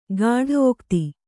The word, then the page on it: ಗಾಢೋಕ್ತಿ: gāḍhōkti